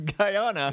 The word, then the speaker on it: Guyana: Unless you're mispronouncing Ghana, and I don't think you are. – It's Guyana.